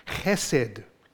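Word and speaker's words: Chesed (חסד): The Hebrew word is spelled in English H-E-S-E-D, hesed, but it is pronounced with what seems like a rolling K at the front of it. Chesed, coming from the back of the throat.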